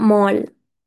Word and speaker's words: mall: مول اللي هو المول كلنا بنروحه، بعد كده ما بنقولش المول ماشي، نقول مول، أنا روحت المول، بعد كده عرفت إن هي بتنطق مول، مول MALL.